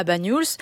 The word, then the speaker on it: Banyuls: Je trouve qu'il a presque un nez de Banyuls. Comment on le prononce ? – Banyuls. Oui, ouais. C'est ça.